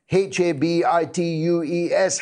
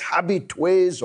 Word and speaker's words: habitués: I'm getting some stick from some of the metropolitan elite in uh, through the glass here about my pronunciation of the French word H A B I T U E S, habitués or habitues as we'd call them in Dundee.